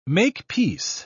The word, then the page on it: make-peace: British English